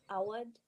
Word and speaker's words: Awad: So it's pronounced Awad, not Awad. – Awad. But honestly, like my family has just given up on the pronunciation for the longest time. I thought it was Awad because just everyone just kept calling us Awad. So it was just like we just kind of gave up. We're like, okay, sure.